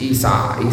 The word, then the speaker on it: Esau: At least you can say it right, correctly. – It's Esau, Esau.